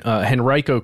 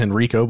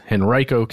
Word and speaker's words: Henrico: But what I did find is a decoy airbase that was constructed for real, fairly close to an airport in Richmond, in Henrico County. And that's how you say it, by the way. I thought it was Henrico, but it's Henrico County.